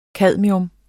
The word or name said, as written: cadmium